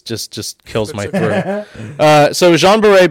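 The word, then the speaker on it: Jeanne Baret: Jean Barret, by means of disguise. – Say it like a French person. Like a French hierarchy. – Like Jean Bray.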